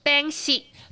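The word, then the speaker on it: 扁食: 那另外就比较特别，就是啊，从一一那边来的哈，一一花莲来的叫边西。 – 边西，对，其实它是来自于闽南语的发音，就是边西。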